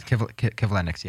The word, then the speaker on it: Kivlenieks: the death of Matisse Kivlenyk. Did I say that right? – Yes, Kivlenyk, yes.